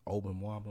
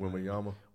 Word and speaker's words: Wembanyama: They get Obenwamba, whatever his name is. I can't never pronounce it. – Wembanyama.